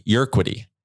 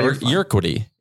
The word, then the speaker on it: Urquidy: And Houston is going with Jose Urquidy. I always butcher that. Did I even get close? – I think you did. Yeah. – Urquidy. It's just tricky because I always want to put the R on the other side of it.